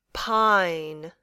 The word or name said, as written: pine